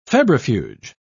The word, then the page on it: febrifuge: British English